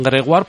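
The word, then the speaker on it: Grégoire: pues se enfrenta contra Gregoire Barrer, y digo bien Gregoire porque la oí cuando me enseñaron francés se pronunciaba UA.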